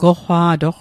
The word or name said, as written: goqqwa'toq